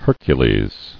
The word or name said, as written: Hercules